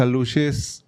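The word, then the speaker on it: alux: La cosa es que eh, yo conocía nada más lo de los aluches, porque se pronuncia aluche.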